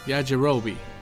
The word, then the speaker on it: Yajirobe: Yajirobe. – Hey, you said his name right.